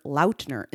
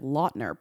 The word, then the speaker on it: Lautner: News on February 3rd to promote his latest partnership with Courtyard by Marriott, Taylor introduced himself by his full name, revealing the first syllable of his last name actually rhymes with out and not lot. So that would make him Mr. Taylor Lautner instead of Lautner.